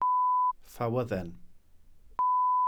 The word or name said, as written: ffawydden